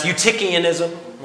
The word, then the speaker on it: Eutychianism: That starts with an E. That's pronounced Eutychianism, okay?